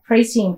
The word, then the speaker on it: pristine: Pristine, P-R-I. – Yeah. – S-T-I-N-E. Yeah, P-R-I-S-T-I-N-E. Pristine. 注意这个 stress, the second syllable.